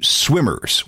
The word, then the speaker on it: SWMRS: And then the group changed their name to Swimmers, which is spelled S-W-M-R-S, while Jacob went off with his own band called Saint Eddie.